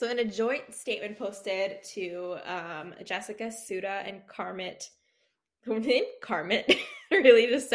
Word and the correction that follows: Carmit: You're right, Bahar. Carmeet.